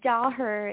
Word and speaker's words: Daher: Yes, Daher is how my the American way of pronouncing it, but don't ask me to do it with the in it. I can't do it.